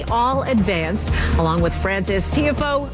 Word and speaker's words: Tiafoe: I know Tiafoe. – Tiafoe.